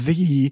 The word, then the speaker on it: Thee: Obviously, we do not wish that upon Megan Thee Stallion, T-H-E-E. – Yeah. – Thee. – You said it wrong earlier. So yeah, I'm sorry. – Said Megan Stallion.